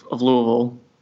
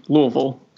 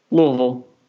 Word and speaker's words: Louisville: We got to work on your pronunciation of Louisville, Josh. Louisville. Got to make it more guttural. – I'm from Florida. – Louisville.